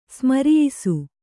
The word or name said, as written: ಸ್ಮರಿಯಿಸು